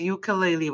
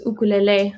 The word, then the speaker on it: ukulele: That pronunciation is based off of Hawaiian pronunciation. So they pronounce their U's rather than U. It's like U. So it's ukulele. Same with, I think, in most Polynesian dialects, they speak with that kind of pronunciation.